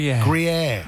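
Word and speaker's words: Gruyère: Gruyere.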